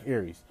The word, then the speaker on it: Ayres: That's Mac, and then Ayres is spelled A Y R E S. Is that Aries? I think it's Mac Mac Aries. Yeah, Mac Aries.